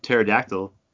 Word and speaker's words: pterodactyl: Why can't you hear a pterodactyl go to the bathroom? – Because the P is silent.